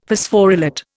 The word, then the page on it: Phosphorylate: Indian